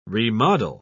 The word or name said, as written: remodel